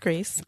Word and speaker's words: Greece: So I want to know about Greasy. – Well, it's pronounced Grease.